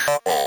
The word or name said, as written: couple